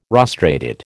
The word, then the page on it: rostrated: American English